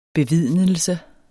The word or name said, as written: bevidnelse